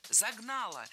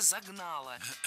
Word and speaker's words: загнало: Загнало. Неверно. Правильный вариант – загнало. И здесь есть одна общая тенденция. В проблемных словах прошедшего времени среднего рода, то есть там, где мы сомневаемся, ударение на окончание не ставим категорически. Поэтому только загнало.